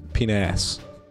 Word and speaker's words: panache: I'd like to use the ability panache and make you guys all roll a insight check. – It's pronounced penass.